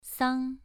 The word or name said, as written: sang2